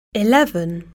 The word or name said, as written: eleven